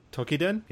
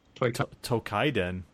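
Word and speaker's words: Toukiden: Is that how you're supposed to pronounce it? – Tokiden? Yes. – How do you pronounce it? – Can you say it one more time? – Tokiden. – I think I did Kaiden. – Tokaiden? No. There's no A there.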